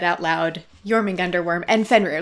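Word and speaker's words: Jörmungandr: Jörgunder? – It's the Jormungandr worm, I think is how you say it. – Nice. – I think it would make it a Jormungandr. – Jormungandr, thank you.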